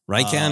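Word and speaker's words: Rieekan: or how are you pronouncing his name? – I always said General Rykan. – Rykan is probably more correct.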